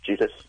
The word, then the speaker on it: Jesus: I was told ahead of time it was Jesus with a hard J.